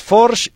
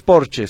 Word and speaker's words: Forge: Es Forge y no es Porsche, sino Forge.